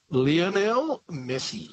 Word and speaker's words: Lionel Messi: Lionel Messi.